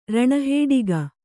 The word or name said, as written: ರಣಹೇಡಿಗ